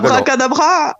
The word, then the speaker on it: abracadabra: Yo creo abracadabra. – Que no. – Abracadabra. Eso es, el acento al final.